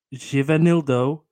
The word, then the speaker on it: Givanildo: It's the first word is spelled Givanildo. Givenildo.